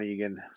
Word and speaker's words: Egan: It's just my name, Sean Egan, spelt with an E-N.